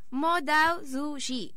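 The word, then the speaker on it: Mo Dao Zu Shi: Es, aquí me va a costar pronunciar cosas. Mo Dao Zhu Xi. – Perfecto.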